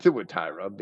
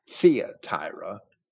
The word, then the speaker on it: Thyatira: In Revelation chapter 2, where chastising the church and in Thuatira, he says, and I pronounce that Thuatira because it shouldn't be a Y, it should be a U, but mainstream denominational Christians might say Thyatira.